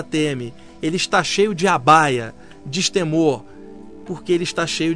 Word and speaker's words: abhaya: Por isso essa palavra abaya, que se escreve no nosso idioma A-B-H-A-Y-A, abaya, significa o destemor daquele que está possuído pelo amor.